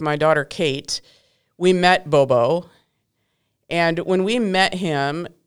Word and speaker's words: Gborboe: And that's not how it's spelled. It's not like B-O-B-O, right? – No. – Okay. – So, yeah, Bobo, his name is spelled G-B-O-R-B-O-E. Bobo.